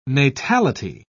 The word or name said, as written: natality